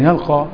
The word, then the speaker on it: يلقى: يا شيخ محمد انت ما انت بتكلم الالف طبعا، يلقى الف مش هي الف لينه بتنطق الف وتكتب ياء.